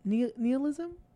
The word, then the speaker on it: nihilism: So that goes back to whenever this movie first came out and everyone was talking about, I don't know how to pronounce the word. Is it nihilism? – Nihilism.